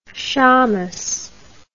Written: shammash

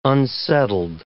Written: unsettled

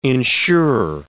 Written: insure

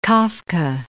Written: Kafka

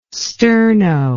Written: Sterno

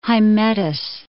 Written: Hymettus

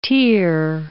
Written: tear

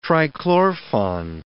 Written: trichlorfon

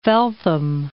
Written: Felltham

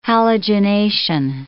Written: halogenation

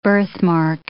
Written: birthmark

